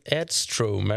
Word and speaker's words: Edström: Femte plats, David Edström. Är det så man ska uttala det, eller? – Det är precis så man ska uttala det.